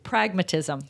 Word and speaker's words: pragmatism: We were putting the emphasis on the wrong part. Pragmatism.